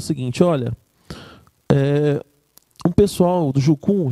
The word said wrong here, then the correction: Jocum: já falar? Jocum. É, sim. Jocum, falei errado.